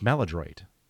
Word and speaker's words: Maladroit: It's Maladroit.